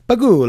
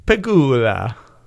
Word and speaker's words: Pegula: I think you have to say it with the Italian. Pegula. You have to say it like with the Italian okay. accent. You got to use your hand, too. – Terry Pegula. – Yeah, that's good. Yeah, much better. – I don't know if he's Italian. – It doesn't matter. That's just how it's pronounced.